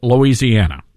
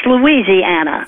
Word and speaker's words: Louisiana: They misspelled Louisiana. – Yes, there's an I in there. A lot of people pronounce it Louisiana. It would be if the I wasn't in there, but it's Louisiana.